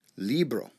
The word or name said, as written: libro